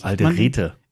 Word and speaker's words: Alderete: Du sprichst Alderette so komisch aus. Entschuldigung, Alderete.